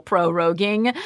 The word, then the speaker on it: proroguing: It's a thing they call proroguing, which also ridiculous that you guys have a word for it anyway. – And it only rhymes with Joe Rogan.